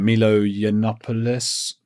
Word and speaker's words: Milo Yiannopoulos: But after that, Milo Yiannopoulos, do you know who he is? – I know exactly who he is, and I'm impressed that you pronounced his name correctly.